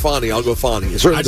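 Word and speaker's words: Fani: The way she pronounces it, Fonnie. But most people just call her Fanny.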